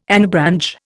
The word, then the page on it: anabranch: Indian